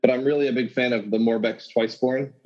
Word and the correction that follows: Morbidex: Morbidex. Sorry, there's Morbidex. Get his name right.